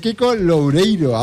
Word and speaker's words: Kiko Loureiro: Kiko Loureiro, ahora lo he dicho bien, joder, ahora macho.